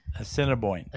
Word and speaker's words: Assiniboine: Assiniboine.